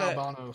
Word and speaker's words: Albano: Yeah, I got Albano. Sorry, I said Albino.